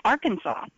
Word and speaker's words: Arkansas: Well, Arkansas is the way it's spelled and the way we would ordinarily pronounce it, but it's Arkansas.